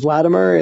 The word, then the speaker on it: Vladimer: Uh, the funky spelling is Vladimer. It's V-L-A-D-I-M-E-R. People often will spell it differently, but it's Vladimer, I-M-E-R.